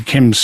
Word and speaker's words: d'Yquem: He couldn't pronounce a Chem, because a Chem's sort of unusual spelling. – D apostrophe Y-Q-U-E-M, is that it? – Small d, right. Apostrophe, capital Y-Q-U-E-M, pronounced a Chem, the D is silent.